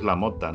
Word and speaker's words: LaMotta: Es, eh, en realidad es así, como lo decimos, es la mota, ¿no? Porque es apellido italiano.